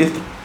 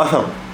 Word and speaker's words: إثر: طيب تتمة المسألة عنده قال الأصح أي أصح أنه أي التكليف يعلمه المأمور إثر الأمر إما تنطق يعني إثر أو أثر بفتح أول وثاني وبكسر أولي وإسكان ثاني أي عقد، يبقى أول يبقى أثر أو إثر هو المقصود بها العقد.